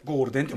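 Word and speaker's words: Goldn: で、ゴールデンっていうラッパー名なんですけど、本名がゴールデン君ちょっと書き方が G、あの、ゴールド N ででゴールデンって読ますよね、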